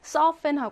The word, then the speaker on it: surfen: 어차피 이게 영어에서 온 단어이기 때문에 서펜 하고 발음하시면 됩니다.